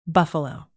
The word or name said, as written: buffalo